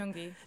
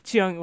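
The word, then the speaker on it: Chingonyi: I think it came out at the beginning of June by the poet, I'm going to butcher his name, Keo Chigoni, right? – Chiongi. – Chiongi, right, thank you.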